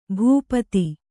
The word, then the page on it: ಭೂಪಥ: bhū patha